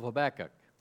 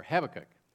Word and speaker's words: Habakkuk: And our scripture this morning is found in the Old Testament book of Habakkuk, or Habakkuk. Depends on which syllable you put the accent.